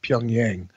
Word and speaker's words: Pyongyang: But I think the consensus is, at least here in the United States, it's pronounced Pyongyang. Okay. I've heard it Pyongyang. I've heard it different inflections, but I say Pyongyang.